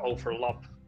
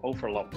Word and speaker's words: overlap: Maar maar dat er dan wel weer een hele duidelijke overlap. Uh, overlap uh, de klemtoon dus verkeerd.